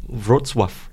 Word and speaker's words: Wrocław: Wrocław.